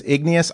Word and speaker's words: Igneous: We often get confused with Ingenious, so if you spell it that way, you'll end up somewhere else, but it is Igneous like the rock, like the volcanic rock.